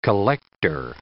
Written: collector

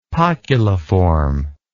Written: poculiform

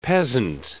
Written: peasant

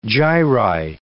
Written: gyri

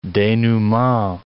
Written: denouement

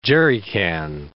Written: jerrican

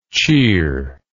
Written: cheeringly

